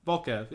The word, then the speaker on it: Volkov: So one of the Bulgarian beaters, Volkov, how do you say, Volkov, name assistant? – Yeah, Volkov, yeah, Volkov, yeah. – Volkov, like the Russian Volkov, right?